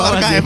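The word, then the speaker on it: archive: Ini bacanya arsip kan, arsip. – Arsive? – Aduh. – Bener enggak? Bener enggak, teman-teman? – Salah. Yang bener apa dong? – Iya, bener sih. – Apa tuh arkaif? Ada sih tulisannya sih, bener sih. – Arkaif, yes.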